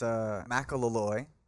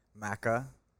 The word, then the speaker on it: Macalolooy: So it's Macalolooy, broken down a little bit slower there. It's Macalolooy.